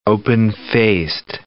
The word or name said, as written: open-faced